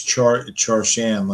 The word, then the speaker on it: Charshan: No, it's Charshan, like Shah Iran and Charlie Chan mixed together.